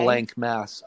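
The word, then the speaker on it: Blanck Mass: and it's spelled B-L-A-N-C-K. – B-L-N-C-K. – Yeah, so okay. – It's Blank Mass.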